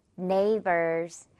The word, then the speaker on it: neighbor: 마지막 단어 조금 어려울 수 있는데 길어져서 역시 GH는 빼고 neighbor만 남습니다.